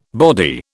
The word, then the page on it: body: British English